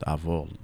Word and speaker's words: Avole: But I really stuck with Avole and spelled it out a little different.